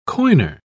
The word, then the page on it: coiner: British English